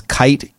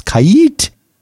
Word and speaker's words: Kiite: It's Kait, and it's spelled K-I-I-T-E. That's why it's Kait.